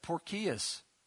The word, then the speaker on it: Porcius: That's how you spell pronounce his name. It's Porcios. This is ridiculous. Paul's got all kinds of problems, and here comes Porky the Pig. That's really, Porcius is his name.